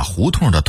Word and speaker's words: 胡同: 胡同这个词儿是典型的北京话，很多南方人说不准胡同这俩字儿，很生硬地把胡同的同读成同志的同，其实它应该儿化韵，读痛，同发痛快的痛的音。